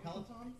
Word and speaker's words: Peloton: I would like a Pelotonin. – Those are expensive. – Yeah. – Peloton? – Yeah, Peloton. Is that how you say it? – I was like, is that a medicine?